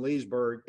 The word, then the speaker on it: Leesburg: But one of the big differences between Wildwood and Leesburg is you do pronounce the L in Leesburg.